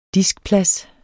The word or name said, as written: diskplads